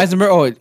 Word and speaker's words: Eisenberg: Like I always go back to the Social Network with Zuckerberg, um, Jesse Eisenberg, and Eisenberg. Eh, you know what I'm talking about? No, no, you said it right. You said oh, it. Right. Eisenberg.